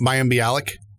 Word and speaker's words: Mayim Bialik: I really liked the interview with I'm going to butcher her name. Mayim Bialik. Is that what her name is? – Mayim Bialik. – Bialik.